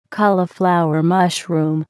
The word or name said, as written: cauliflower mushroom